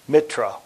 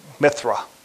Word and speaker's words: Mithra: In Hinduism, you got Surya, and other Indian gods include Riva and Saranu, and an Indo-Persian god of the sun is Mitra or Mitras. Mithra. There's a couple of different pronunciations, and depending on if you throw the H in there or not.